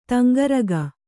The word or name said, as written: ತಂಗರಗ